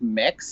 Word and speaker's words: Mx: The closest thing I found is MX, and you pronounce it like mix, so it'd be like, I would be like Mix.